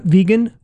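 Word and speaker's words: Vigan: There's a city called, appropriately enough, Vegan. – Yeah, really? – Yeah. – Wow. – But it's spelled with an I. It's V-I-G-I-N.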